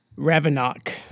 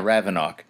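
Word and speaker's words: Ravinok: Ravenok. – Yeah, Ravenok.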